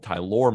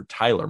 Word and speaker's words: Tylor: Although he prefers being called Tylor McGill over Tyler McGill.